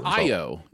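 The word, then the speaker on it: Ayo: It's Io.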